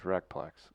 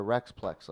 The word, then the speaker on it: Recplex: So, of course, we go to the St. Peter's Recplex. Big deal. – Hey, you said it right. – Hour and a half drive. – You didn't call it the Rexplex like some do.